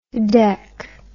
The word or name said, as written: dec